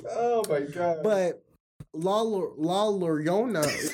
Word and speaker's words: La Llorona: It's pronounced like with a Y. It's like yo yo. La Llorona. There you go. Yes. yes. But uh, roll your R when you do it. La Llorona.